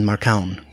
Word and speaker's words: Marcão: So I can tell you it's Olhado and Novinha and Marcão, but there's actually in my Nook version there's actually a pronunciation guide for the names.